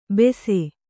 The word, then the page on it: ಬೆಸೆ: kannada